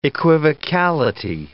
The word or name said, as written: equivocality